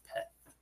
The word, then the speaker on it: Peth: Yeah, so the letter in P in Star Wars language is pronounced pet. – Gotcha. – P-E-T-H.